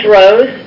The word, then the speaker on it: Droz: Okay, my name is Dana Droz. First name is spelled D-A-N-N-A. Last name is spelled D-R-O-Z.